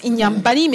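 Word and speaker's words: Inhambane: Ah, Ça se prononce Inyambani, mais c'est Inhambani.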